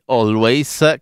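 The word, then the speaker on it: Alvvays: Comunque, adesso andiamo in Canada e andiamo dagli Always, che però attenzione, non si scrive con la W ma con due V, Alvv Ays.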